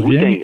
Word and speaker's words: Wu-Tang: De Wu-Tang, j'espère que je vous prononce Wu-Tang. – Bien.